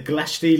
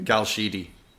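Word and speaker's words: Glashedy: Is that how you pronounce it? – Galshidi. – Galshidi. There you go. Completely wrong from my perspective. I'll call it Ballyliffin. It's quite. – It's Galshidi. You have a okay.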